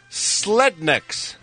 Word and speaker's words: Slednecks: Called Slednecks. slednecks. They're not rednecks, Kevin. It rhymes with red.